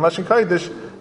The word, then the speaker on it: Lashon Kodesh: Masha Eke and Lashon Kodesh, that's the correct way to say it.